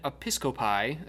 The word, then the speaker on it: Episcopi: A document called the Canon Episcopi asserted that women joined the... Yes, that is the official pronunciation.